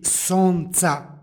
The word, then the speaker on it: sonca: stihu izgovorili smo sonca. Srpski izgovor ne trpi gomilanje suglasnika i u duhu srpskoga ovdje jedan suglasnik u izgovoru ispada. Pošto je u srpskom izgovor sunca, ovdje će biti sonca bez l.